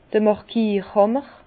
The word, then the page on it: d'r Màrkierhàmmer: Bas-Rhin; Herrlisheim